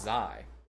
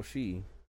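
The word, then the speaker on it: Xi: I always thought it was pronounced Zai. Is it Zai? Because it's spelled X-I-C-H-I-P-I-N-G, right? Zai Jinping? – I think they call him Uncle Shi.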